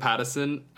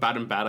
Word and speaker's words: Pattinson: Well, and those little moments, like when him and Robert Pattinson, Robert Battenbat, I slipped up there.